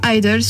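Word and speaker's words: (G)I-DLE: Enfin, il y a un G au début, mais il est silencieux. Donc on le prononce soit Idol, soit Yeojan Idol.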